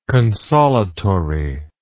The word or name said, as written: consolatory